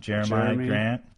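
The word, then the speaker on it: Jerami Grant: But otherwise, we got Harvey Grant's son, Jeremy Grant. Is that how you pronounce it? – Yeah, he's only 21.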